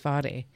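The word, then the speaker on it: Whare: And I think Ware is actually, it's WH and it's pronounced with F, like Te Ware Tewekaweka, from 2025.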